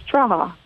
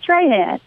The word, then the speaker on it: Trahan: Now, and look, I'll tell you, if you ever talk to Abby, it's Traha. It's not Trahan. She's going to get you. She's good. She's one of those cages that pronounce it a little bit differently. So Traha, but kind of like ha-ha when she strikes you out at the plate or something.